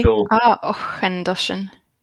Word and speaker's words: Auchentoshan: Okay, so you're spot on, other than you're pronouncing the CH as a hard K. – Okay. – Auchentoshan. – Yeah, Auchentoshan. It's what's called a velar fricative at the back of the throat there. It's the K.